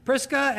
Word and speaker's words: Prisca: But it's a hard k sound, at least in Greek, for both Prisca and Aquila.